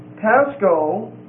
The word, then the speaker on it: pascho: P-A-T-H-O-S. O-S. The word pathos means a passionate desire. Shows an affection of the mind. It comes from the word pasco, P-A-S-C-H-O. It's a long O. Pasco, P-A-S-C-H-O.